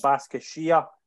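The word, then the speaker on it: Başakşehir: They host Turkish side, Istanbul, Basaksehir. That was great pronunciation there.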